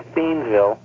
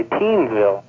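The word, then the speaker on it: Thiensville: It's spelled T-H-E-I-N-S, and then whatever, V-I-L-L-E. And about half the people in Milwaukee say Theensville, and then about half say Teensville.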